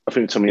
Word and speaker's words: Tomiyasu: Is it Tomiyasu? – Tomiyasu.